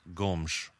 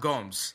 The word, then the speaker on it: Gomes: And so apparently it should be pronounced Gomes. So we'll do our best to stick to Gomes, but we might just fall into Gomes and other things like that. But those are not nicknames. We're just trying to actually pronounce his name. – Your Portuguese is on point, my friend. So, yeah, it's pronounced Gomes, right? Like, that's how it's pronounced.